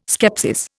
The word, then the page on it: skepsis: Indian